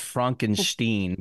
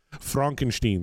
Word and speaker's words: Frankenstein: I actually heard from a Dutchman it's pronounced Frankenstein, but okay.